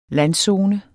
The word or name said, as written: landzone